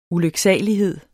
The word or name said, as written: ulyksalighed